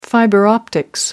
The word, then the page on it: fiber optics: American English